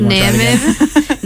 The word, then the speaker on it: Namib: Why the heat beating down on waterless dunes in the Namib. I'm really bad at geography. – I think you mispronounced that. – Namib. No. – One more time for luck. – It's like a... Yeah, there's an N-A-M-I-B in there.